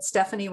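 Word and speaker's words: Stephani: So you can find me at Stephanie Robson.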